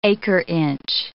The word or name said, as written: acre-inch